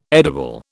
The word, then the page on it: edible: British English